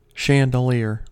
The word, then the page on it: chandelier: American English